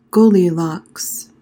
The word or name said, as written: goldilocks